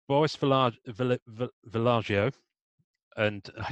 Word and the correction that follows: Vallejo: I think it's Vallejo.